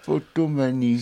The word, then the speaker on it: potomanie: Alors les affreux Jojo, est-ce que vous savez ce que c'est que la potomanie? – Ah, potomanie, c'est dès qu'on voit un poteau, on l'embrasse.